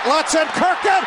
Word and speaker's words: Lutzenkirchen: Lutzen Kirkin. Okay, it's a mispronunciation on my part.